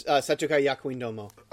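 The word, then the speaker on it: Setokai Yakuindomo (生徒会役員共): I don't want to say it's like Shimaneta, but it reminds me of that other, like, Setokai Yakudomo. I think that's how pronounce it. – Yes, Setokai Yakuindomo.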